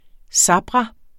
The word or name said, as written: sabra